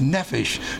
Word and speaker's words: nephesh: No, it's not the nephesh. There's no fish in it. It's nephesh. Short E is pronounced eh, nephesh. And the nephesh is the word for soul.